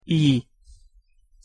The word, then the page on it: i: Hungarian; hosszu